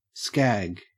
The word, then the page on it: skag: Australian English